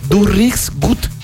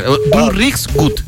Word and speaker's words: Du riechst gut: Entonces dice, Du riechst gut. Entonces, así se pronuncia. Du riechst gut.